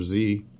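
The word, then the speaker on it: Sze: I brought one by Arthur Z. That's S-Z-E. I like to pronounce it Z.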